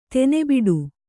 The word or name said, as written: ತನಿಬಿಡು